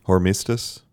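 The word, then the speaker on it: Hormisdas: Pope Saint, how do you say this? – Hormistus. – Hormistus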